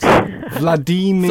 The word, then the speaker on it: Vladimir: But he also says Vladimir rhymes with redeemer. So it's Vladimir. – Vladimir. – In the Russian pronunciation, yeah.